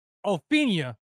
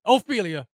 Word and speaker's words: Ophelia: Torrential downpour facing not only the Washington Commanders, but Tropical Storm Ophelia or Ophelia. Is that her name? Ophelia? Ophelia. My bad. I got your name wrong.